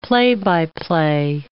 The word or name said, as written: play-by-play